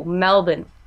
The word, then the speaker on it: Melbourne: We're going to get you saying it like a local. Melbourne. – Melbourne. – Melbourne. – Yeah, the R is silent.